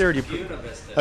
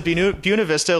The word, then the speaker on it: Buena Vista: Buena Vista. – I pronounce it Buena Vista, like the school in Iowa in Division III that everybody knows about.